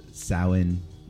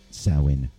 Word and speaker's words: Samhain: So I'm going to play what immediately comes to mind during spooky season is a band called Samhain. Looks like Samhain, but it's pronounced Samhain apparently if you're Celtic.